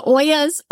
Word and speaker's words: olla: Like, what's an ala? – Okay. So ala, O-L-L-A. It's actually pronounced olla. So it's a Spanish name.